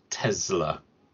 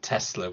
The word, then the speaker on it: Tesla: And if I might just pick up on one small detail from this week's Smith & Sniff, it is pronounced Tesla. – Oh, yes. – With the Z, not Tesla with the S. Just clearing that up, because that is how it's pronounced in the original Serbian. Tesla, not Tesla, definitely, because it's named after the man and he was Serbian and that's how it would be pronounced in Serbia.